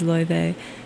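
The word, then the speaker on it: Laufey: but I just recently learned I'd been pronouncing her name wrong. I believe that, or as close as I can get to it, the way to pronounce it, the Icelandic pronunciation is Loive, so I just learned something new.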